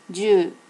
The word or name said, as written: ju